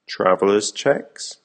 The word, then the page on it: traveller cheques: American English